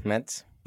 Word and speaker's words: Metz: Metz is the pronunciation, is it? – Yeah, Metz.